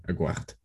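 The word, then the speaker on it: Aguerd: His name was Aguert. I butchered that so hard. Aguert? Anyways, it's like Aguero, but without the O, with a D. Aguert.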